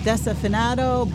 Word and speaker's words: Desafinado: Desafinado. Did I pronounce that right? – Desafinado Bossa Nova. – Yes.